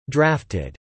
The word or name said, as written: drafted